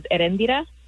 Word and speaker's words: Erendira: Well, my actual real name is Erendira, and my nickname is Eddie. But it's spelled E-R-I, so you're supposed to roll the R.